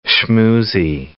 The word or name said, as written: schmoozy